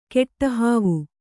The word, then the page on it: ಕೆಟ್ಟಹಾವು: keṭṭa hāvu